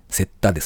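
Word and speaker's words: 雪駄: あの、雪に下駄の田と書いて、セッタですね。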